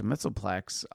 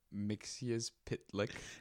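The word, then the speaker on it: Mxyzptlk: First of all, you're pronouncing his name incorrectly. – I'm pronouncing it in the way that I care to pronounce it. – Mixius Pitlick. – No, that sounds stupid. – Okay. – Mr. Mitzelplex sounds better. – But it's wrong.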